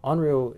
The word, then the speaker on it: Henriot: So Onrio is, it smells, it it's spelled like Henriot, so mm-hmm. like H-E-N-R-I-O-T.